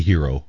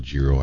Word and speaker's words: gyro: They say hero. – Yeah, I don't know. – They say hero. I call it gyro. I say the G.